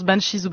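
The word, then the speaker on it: Banxhies: Alors le X est écrit en grand car il barre un S qui nous a été cher payé puisqu'on s'appelait The Banshees, hérité d'une figure de la mythologie gaélique. Je vais pas trop m'étaler là-dessus, mais on a dû changer de nom. Ça se prononce Banshees ou Banshees, c'est vraiment comme on veut.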